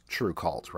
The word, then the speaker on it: TRVEKVLT: And just to clarify for anybody listening who wasn't watching earlier, it's spelled TRVEKVLT, but pronounced True Cult, right?